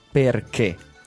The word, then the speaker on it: perché: Only one thing in the dialogue is not really clear because he's speaking with food in his mouth, but the pronunciation is perché.